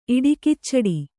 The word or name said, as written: ಇಡಿಕಿಚ್ಚಡಿ